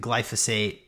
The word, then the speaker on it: glyphosate: So glyphosate, I pronounce it glyphosate, and it's basically Roundup.